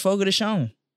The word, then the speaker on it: Fogo de Chão: It's actually pronounced Fogo de Chão. – Is it? – It's spelled chow. It's spelled chow.